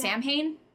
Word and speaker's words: Samhain: The next is Samhain, which is pronounced Samhain.